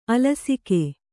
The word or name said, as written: ಆಲಸಿಕೆ